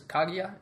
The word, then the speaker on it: Kaguya: Princess Kaguya, is that how you say it? – Yeah.